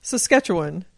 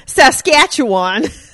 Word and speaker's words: Saskatchewan: How do you pronounce that place? – Well, after the show, I pronounce it differently. Saskatchewan. Yeah. Before, I would have been Saskatchewan.